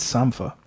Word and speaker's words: Sampha: I'm not sure how you pronounce it. – It is. I think it's Sampha. It's S-A-M-P-H-A.